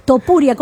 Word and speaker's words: Topuria: Tupuria, como digo sí. Yo. ¿Algún padre quiere que el niño sea Tupuria? Topuria, ¿cómo se llama el individuo? Siempre lo digo mal. – Sí, Topudia.